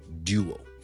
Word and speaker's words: duo: 今日咧想同大家讲一个英文字嘅发音，呢个英文字就系 D U O duo duo duo duo，即系两件嘢即系埋一齐，我哋叫一个 duo。最近呢个字好多人讲，因为某一个电话咧，那个型号咧系有呢个英文字嘅，咁啊好多人都讲呢个字嘅发音 duo duo duo。如果系英式英文发音咧，前面咧系一个 duo，好似一个 D 音加个 U 咁啊，谂埋一齐变咗 duo duo duo。